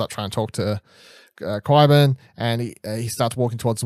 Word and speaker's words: Qyburn: So then Tyrion moves up, heads towards the wall, and then Qyburn, is it Qyburn? – Qyburn, thank you.